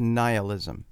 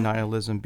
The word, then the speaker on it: nihilism: Now, you may say, wait a minute, I thought this word was pronounced nihilism. Okay, word to the wise, if you prefer to use the pronunciation nihilism, be my guest. Some people have said that they prefer nihilism, and so I hereby authorize you to maintain, if you prefer, the pronunciation of nihilism.